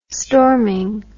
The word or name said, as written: storming